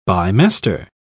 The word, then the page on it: bimester: British English